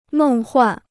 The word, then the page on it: 梦幻: mèng huàn